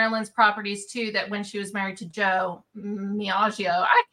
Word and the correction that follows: DiMaggio: you can't say his name. – Joe DiMaggio. Yeah. Joe DiMaggio.